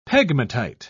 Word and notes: pegmatite: British English

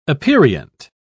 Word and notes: aperient: British English

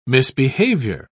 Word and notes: misbehaviour: British English

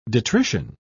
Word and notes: detrition: British English

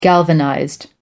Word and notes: galvanized: American English

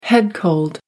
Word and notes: head cold: American English

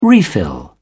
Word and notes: refill: British English

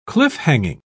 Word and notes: cliff-hanging: British English

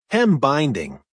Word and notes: hem binding: American English

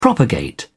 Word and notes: propagate: British English

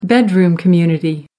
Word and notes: bedroom community: American English

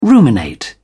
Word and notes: ruminate: British English

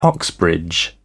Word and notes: Oxbridge: British English

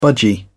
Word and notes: budgie: British English

